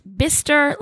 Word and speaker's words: Bicester: A lot is pronounced bister like blister without the L. Bister. What happens to the C and the E? Exactly. – Because I'm sure to I me, called it bicester.